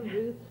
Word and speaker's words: Ruth: Rebecca Ruth Schaefer. – And spell it, please. – The whole name, R-E-B-E-C-C-A, Ruth, R-U-T-H, Schaefer, S-C-H-A-E-F-E-R.